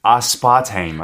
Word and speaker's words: aspartame: 특히 마지막에 들렸던 aspartame as part time 이렇게 발음하는 거 맞나요? – I thought it was as part of me. but yes, it's aspartame, I think.